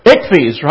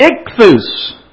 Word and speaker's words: ichthys: Now, if you pronounce it the way it would look in English, you would say ICHFES, right? It's ichthus, because this is, you know, the fish symbol?